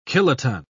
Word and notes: kiloton: British English

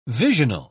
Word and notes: visional: British English

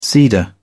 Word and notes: cedar: British English

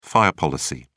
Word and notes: fire policy: British English

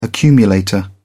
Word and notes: accumulator: British English

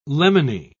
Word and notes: lemony: British English

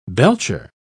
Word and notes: belcher: British English

